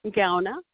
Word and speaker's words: Gaona: Sandy, S-A-N-D-Y. – Yes, oh, Sandy. And your last name? – Gauna. – Can you spell it, please? – G-A-O-N-A. – You say G-A-O-N-A? – Yeah.